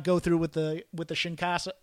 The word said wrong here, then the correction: Kinshasa: or Chinkasa or however you Kinshasa. – Pronounce it. Kinshasa, thank you.